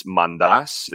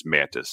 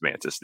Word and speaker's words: Mantas: So I'm Greek, and so it's technically it's Mandas is how you say it in Greek, but uh-huh. You know, since it's all, you know, been Americanized, everybody just says Mantis, so it's yeah. Fine, just Mantis, Nick Mantis, so.